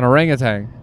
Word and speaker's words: orangutan: There's no G at the end of that. – Then what? How do you say it? – Orangutan. – Orangutan. – But you're adding a G like it's fucking orange juice.